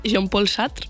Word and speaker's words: Jean-Paul Sartre: Mal pronunciados, tú eres la que sabe francés, Jean-Paul Sartre.